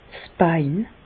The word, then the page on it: 's Bein: Bas-Rhin; Reichshoffen